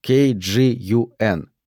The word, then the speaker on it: KGUN: «Но выстрелы были сделаны с дальней дистанции?» — спросила черная женщина-репортер из, как это ни смешно, KGUN. – Название канала читается как K-Gun, а «Gun» означает «огнестрельное оружие».